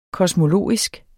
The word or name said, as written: kosmologisk